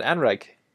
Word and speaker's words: anrheg: We're gonna go with this Welsh word is pronounced unrech. – That's not bad, Anreg.